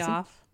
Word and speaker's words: Piaf: Is that how you say it? – Piaf. – Piaf.